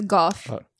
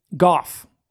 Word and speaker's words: Gough: Is it Gough Whitlam? Is that how you say it? – That's Gough. – Gough. Really? Mm-hmm. Gough. – Am I wrong? – No, it's Gough Whitlam.